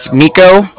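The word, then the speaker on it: Niko: It's Miko. That's N-I-K-O. And then calling with the Bank of Cardiff, and that's spelled C-A-R-D-I-F-F, as in Frank, so it's like car indifferent.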